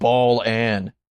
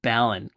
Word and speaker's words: Balan: They pronounced it Balan, and then the English trailer calls it Balan, like it's Alan with a B on the front.